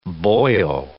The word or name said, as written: boyo